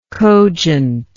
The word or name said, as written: cogently